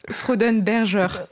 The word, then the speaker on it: Freudenberger: Freudenberger.